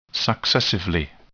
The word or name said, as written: successively